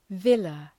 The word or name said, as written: villa